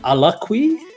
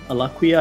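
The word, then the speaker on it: Alaqua: Closing off the episode, we have a close up of actress Alakwi, Alaquia, Alaquia, I think is how you pronounce her name.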